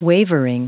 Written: wavering

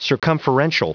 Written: circumferential

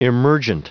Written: emergent

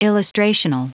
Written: illustrational

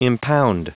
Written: impound

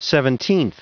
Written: seventeenth

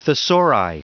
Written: thesauri

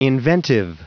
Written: inventive

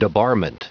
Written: debarment

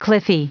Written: cliffy

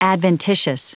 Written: adventitious

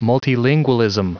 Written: multilingualism

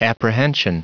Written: apprehension

